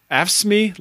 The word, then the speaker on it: AFSCME: Af- AFSCME local 2206.